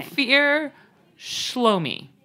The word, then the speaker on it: Ophir Shlomi: Ophir Shlomi. – Okay. – Ophir Shlomi. Sounds... rhymes with blow me.